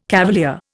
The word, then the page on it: cavalier: Indian